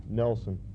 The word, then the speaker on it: Nelson: Devon, capital D-E, capital V-O-N. – And your last name? – Nelson, N-E-L-S-O-N.